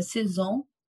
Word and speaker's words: Saison: Era Saison que pronunciava e eu falei Saison. Porque era, li exatamente como estava.